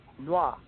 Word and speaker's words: noir: And when you pronounce it, think simply of just N-W-A, noir, code noir, which is the black code.